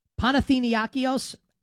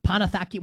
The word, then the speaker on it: Panathinaikos: I should be able to pronounce this correctly. Panathinaikos. I know how to pronounce this. I promise. I'm flubbing it at the moment. Panathaki, whatever it is. One of these Greek teams along with Olympiakos. Panathinaikos, whatever.